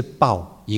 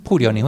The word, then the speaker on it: 瀑: 那其实呢，这个我们如果查字典的话呢，这个有两个读音，一个是爆，一个是瀑啊。那瀑流是瀑流，你会想到什么？